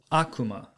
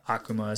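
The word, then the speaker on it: Akuma (悪魔): Today's Lessons is going to be the Japanese word for devil, and that is Akuma. Akuma is spelled A-K-U-M-A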